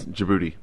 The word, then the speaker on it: Djibouti: It's Djibouti.